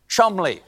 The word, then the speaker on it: Cholmondeley: The Marchioness of Cholmondeley. What a beautiful name that I'm being told right now that I pronounced incorrectly. It is, in fact, pronounced Chumley.